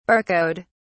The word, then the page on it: barcode: British English